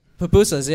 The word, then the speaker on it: pupusas: Pupusas.